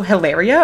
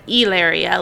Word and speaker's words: Hilaria: She went by Hillary and not Hilaria. Yeah. So it was like Hillary, then Hilaria, and then Hilaria, like the traditional Spanish pronunciation.